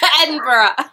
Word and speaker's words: Edinburgh: Anyone listening, when you go to, I don't know how do Americans say Edinburgh? Do we say it like you guys say it? Edinburgh. – Yeah, that's it.